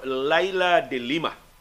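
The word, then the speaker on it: Leila de Lima: ang akong tubag gikan ni sa authority ang akong pronunciation siya nga. Gikan ni mismo ni kanhi senador Laila de Lima.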